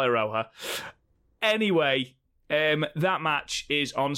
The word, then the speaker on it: Iroha: So, Velky John on Discord said, oh, lovely podcast episode, but it's Iroha, not Ihora.